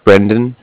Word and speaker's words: Brendan: Yeah, it's Brendan. – B? – B-R-E-N-D-A-N.